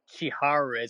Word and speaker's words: Chihiro: How do you say your name in real life? – Chihiro, it's Japanese.